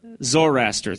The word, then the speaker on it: Zoroaster: The Zoroaster? Zoroaster, thank you.